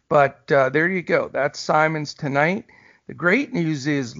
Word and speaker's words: Simons: Anfernee Simons, often mispronounced Simmons.